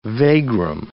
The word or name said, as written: vagrom